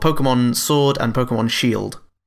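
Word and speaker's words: Pokémon: Moving away from collecting racing trophies to collecting the Pokemon, which is how it's pronounced, by the way. That's what the little umlaut is at the top there. That's what that means. Pokemon.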